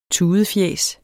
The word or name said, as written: tudefjæs